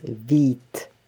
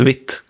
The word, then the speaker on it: Vite: Och nu ser jag också att det ska uttalas vit. – Vit, är det så det uttalas? – Ja, det stavas vite. vite. Vite. Eh, det är tydligen franska för quick och ska uttalas like vit. – Vit. Okej, okay. bra att veta. Jag har sagt vitt väldigt länge. – V-E-E-T.